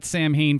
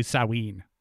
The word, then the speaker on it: Samhain: So folks out there, I had pronounced that Samhain for years. It's actually Samhain. Or Samhain.